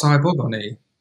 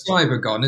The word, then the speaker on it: Cybergon: So I thought, oh, it must be pronounced Cybergon-y. – It's Cybergon, isn't it? – It's Cybergon, yeah.